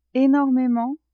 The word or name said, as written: enormement